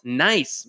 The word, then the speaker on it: Nice: She's from Nice, which is spelled nice, but it's Nice. N-I-C-E.